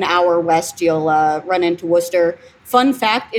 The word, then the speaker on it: Worcester: Worcester, yeah.